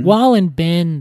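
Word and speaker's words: Wohlleben: But at the same time, this guy who does the book, Wallenberg, I think is Wallenberg? I can't remember his name. He's a German man, and I keep on saying his name wrong. – Peter Wallenben. – Wallenben, that's it.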